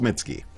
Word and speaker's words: Mitski: Just Mitski. – Mm-hmm. – How do you spell it? – M-I-T-S-K-I.